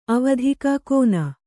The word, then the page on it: ಅವಧಿಕ ಕೋನ: avadhika kōna